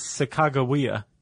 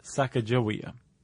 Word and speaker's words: Sacagawea: And in Shoshone, Sacaga is bird and Wea is woman. So it's Sacagawea, not Sacagawea.